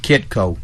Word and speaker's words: Kitco: Kitco. – Spell that for us. – K-I-T-C-O.